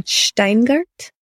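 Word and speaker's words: Shteyngart: Steingart.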